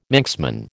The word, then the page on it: Manxman: American English